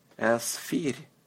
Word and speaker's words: Эсфирь: Эсфирь. Эсфирь. It starts the same as an English S and ends with fir. Fir. Just like a firm, but a soft R.